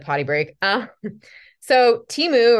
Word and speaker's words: Temu: Somebody sent me a link to I don't know how to say it, T-E-M-U, Temu.